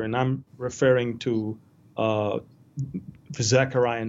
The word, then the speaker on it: Zechariah: we communicated earlier about uh, Zechariah. Did I say it correctly? – Zechariah? Yeah. Zechariah? – Okay.